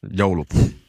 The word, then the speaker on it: joulupukki: Joulupukki. Am I saying it right? – Yeah. Close enough. Joulupukki. Like a story about that shirt was me and Phil, we went to Croatia like what, 4 years ago?